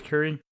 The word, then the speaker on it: Curry: I think it's Curry. It might be. What's it? Marie. I'm pretty sure it's Curry. Because it's kind of spelled like Marie Curie.